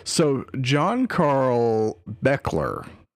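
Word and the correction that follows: Buechler: or Beekler, sorry. It's spelled very not how it's pronounced, but it is pronounced Beekler.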